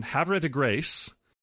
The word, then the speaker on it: Havre de Grace: And let me make sure I'm getting the pronunciation right, the correct Maryland pronunciation, Havre de Grace. – That's exactly right. On both counts.